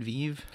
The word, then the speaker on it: Vive: Is that how you say it, Vive? – Absolutely.